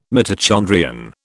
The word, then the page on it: mitochondrion: British English